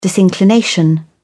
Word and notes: disinclination: British English

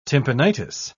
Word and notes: tympanitis: British English

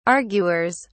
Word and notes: arguers: British English

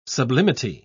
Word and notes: sublimity: British English

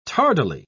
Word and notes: tardily: British English

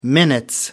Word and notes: minutes: British English

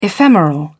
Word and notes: ephemeral: British English

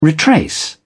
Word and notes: retrace: British English

